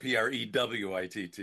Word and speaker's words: Pruitt: And that's spelled, that's the Mississippi spelling, like Jeannie Pruitt, Prewitt. You know, most people go Pruit, which is correct too.